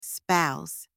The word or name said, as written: spouse